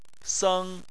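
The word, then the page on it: seng: tone 1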